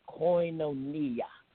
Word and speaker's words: koinonia: The lesson I want to focus on today is koinonia. I want you to try saying that. Koinonia. And it's spelled K-O-I, for those who are taking notes.